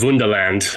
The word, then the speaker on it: Wunderland: And it's kind of, it's called Wunderland, you know, just with the hard German pronunciation.